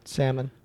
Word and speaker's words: salmon: Salmon. – Oh, salmon. – Salmon. – Salmon. – Salmon. – But salmon. – Is it pronounced salmon? – No, the L silent.